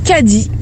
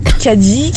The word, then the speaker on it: Caddie: Donc, mes parents m'ont appelé Caddy. Caddy, qui s'écrit C-A-D-D-I-E.